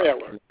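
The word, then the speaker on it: Traylor: Your first name is Henry, H-E-N-R-Y. Your last name is Trolley, Trawloy. Is that correct? – No, no. The last name is spelled T as in Tom, R, A, Y, L, O, R. – L, O, R. – Trailer, like a tractor trailer.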